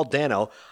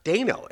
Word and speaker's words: Dano: Every single person has been saying his name is Paul Dano. I just read an article about him. It's actually pronounced Dano. It said it's pronounced, it rhymes with Drano.